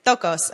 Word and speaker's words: ptochos: I was like trying to say it on my Siri, like, how do you pronounce? And it was like, stokos. And I was like, okay, P-T-O-C-H-O-S.